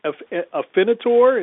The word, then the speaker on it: Afinitor: Well, I want to ask you about this, if I could pronounce it right, Afinitor, is that right? – That is correct.